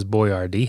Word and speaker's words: Boyardee: It's spelled that way because he wanted Americans. The guy's real name was Boyardee, Hector Boyardee.